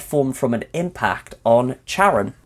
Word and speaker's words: Charon: It has five moons around it, the largest being Charon, or I think it's pronounced Charon, actually. I think everyone just sees the CH and goes, Charon, but I believe it's Charon.